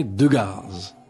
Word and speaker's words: Degas: Alors Degas, d'ailleurs moi j'entendais prononcer son nom par Julie, qui avait été, par mon grand-père, qui avait tous les deux très, très bien connus. Et Julie prononçait Degas. C'était sans doute à l'italienne.